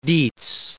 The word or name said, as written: Diez